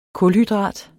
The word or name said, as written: kulhydrat